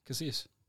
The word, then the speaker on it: Casillas: Is that how you pronounce his name, Casillas? – That's how I pronounce his name, yeah. – Okay. – How do you pronounce it? – Casillas.